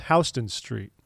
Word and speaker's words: Houston Street: and you see Houston Street, it's pronounced Houston Street. So just say that, and people will think you're a more legitimate human.